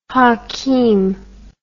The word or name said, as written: hakeem